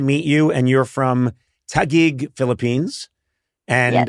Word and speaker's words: Taguig: And how do you pronounce it again? Yes. Taguig? – Yes. Oh, you did pretty well. Yeah. Taguig.